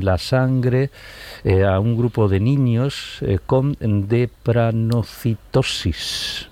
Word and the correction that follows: drepanocitosis: No sé si lo he pronunciado bien. – Es una enfermedad que se llama drepanocitosis.